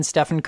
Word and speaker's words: Stephen: Why is the PH pronounced like V in Stephen?